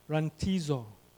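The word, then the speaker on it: rantizo (ῥαντίζω): That's it in Greek at the top, but it's pronounced rantizo, to sprinkle. Rantizo.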